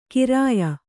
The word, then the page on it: ಖರೆಯ: Kannada